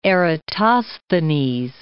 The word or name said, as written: Eratosthenes